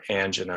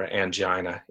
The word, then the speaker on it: angina: In general, blockages don't cause exertional chest pain or angina or angina, either pronunciation is correct, unless that blockage gets to the point where it's about 70% blocked.